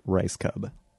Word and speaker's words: Reichscub: Mary Lynn Reichscub? I meant to go over this before. – It's pronounced like rice cub.